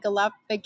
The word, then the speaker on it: Galapagos: And then, of course, they've got a sister ship that came out, the Celebrity Flora, that is actually, you know, in the Galapagos Islands. Did I say that right? Galapagos. – Yes.